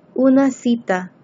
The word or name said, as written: una cita